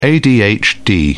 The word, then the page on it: adhd: British English